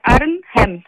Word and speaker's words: Arnhem: Arhem. – Öyle oluyor, evet. – Arhem şehri. Arhem. Mi? Doğru mu? – Bir de ne var? Arnhem. Birazcık telaffuzu zor.